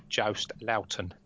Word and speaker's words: Joost Luiten: And you rightly say Joost Luiten, which is the correct pronunciation. it's the only one I can pronounce just because he told us how one day on European tour.